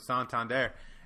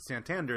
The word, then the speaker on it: Santander: No, because Kevin Brown, I listened to the broadcast when he's pronouncing him as Santander. And he said, Santander came up and said, "Hey, I'm not. It's not pronounced Santander. It's pronounced Santander."